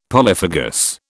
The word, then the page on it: polyphagous: British English